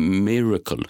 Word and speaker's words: Myrkl: Så är det rätt nu? Miracle. – Ja, miracle. miracle. Inte Miracle. Jo, Miracle. Ja, precis. Men det stavas ju M-Y-R-K-L. Alltså, du uttalar det som det stavas, tror jag. Ja. M-Y-R-K-L.